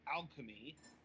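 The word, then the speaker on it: Alkemi: It's from a French developer called Alchemy, with a very odd spelling, A-L-K-E-M-I. I was trying to figure out how to pronounce that at first, but then I saw their logo, and I'm like, oh, okay, it's a play on Alchemy.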